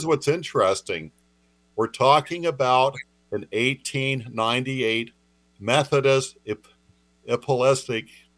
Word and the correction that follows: Episcopal: How did I pronounce that? – Apostolic. – Episcopal. – Oh, Episcopal. Okay.